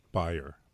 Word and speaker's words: Bayer: In the U.S. is called Bayer. It's like Bayer aspirin, Andy, but you actually call it Bayer. – Oh, is that how it's originally pronounced? – Bayer, yeah. – Correct. – Even though their CEO pronounces it Bayer for the stupid American audience.